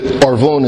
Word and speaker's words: Arvon: That first word there, it's Arvon. Arvon is how it's pronounced.